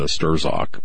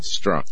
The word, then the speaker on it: Strzok: I pronounced it uh, Sturzok, but apparently the Z is silent. Who knew? Struck, right? Struck, yeah.